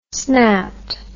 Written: snapped

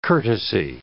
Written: courtesy

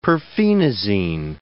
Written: perphenazine

